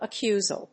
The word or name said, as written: accusal